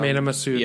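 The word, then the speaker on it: Mena Massoud: So Mena Masood.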